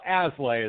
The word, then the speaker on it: Aslay: Well, Aslay is the easiest pronunciation.